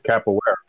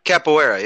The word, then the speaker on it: Capoeira: I think it's Capoeira. – Capoeira, yeah.